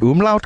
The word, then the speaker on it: umlaut: Am I pronouncing umlaut correctly? – You did, yeah.